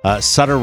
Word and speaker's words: Sutter Creek: Sutter rhymes with butter.